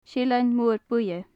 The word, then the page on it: seillean mor buidhe: gaelic